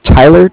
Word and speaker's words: Tyler: My name is Tyler, T-Y-L-E-R, and I am calling from Bank of Cardiff. It's spelled C-A-R-D-I-F-F, as in Frank Frank.